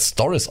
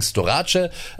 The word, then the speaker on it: Storace: wird ja auch gerne mal Storis ausgesprochen, ist aber gebürtiger Malteser. Also jetzt kein Sanitäter, sondern eben auf Malta geboren. Deswegen Storace.